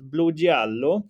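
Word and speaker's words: Blugiallo: Blugiello, jag tror att det ska vara mer A än E där. Så att Blugiello, blågul betyder det.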